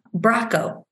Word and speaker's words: Bracco: From Bracco, rhymes with taco.